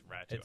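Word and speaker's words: Räty: Why is it a Y on the end of his name when it's Ratu? I don't understand it. – That's just how it's pronounced. It's a Y, but it's pronounced Ratu.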